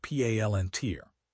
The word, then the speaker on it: Palantir: firms like Palantir, pronounced Palantir. It turns out that when you turn the entire data ecosystem into a surveillance tool, individual digital hygiene becomes a fantasy.